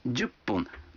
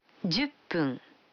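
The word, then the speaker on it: джуппун (十分): А 10 минут – джуппун. Вы заметили, что когда фун добавляется к 10, дю, то произносится пун. – Послушайте и повторите каждую фразу. 5 минут. – Гофун. – 10 минут. – Дюппун.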